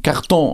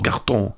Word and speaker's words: carton: Deswegen heißt der Karton auch Karton und wird im Französischen Carton ausgesprochen. – Carton.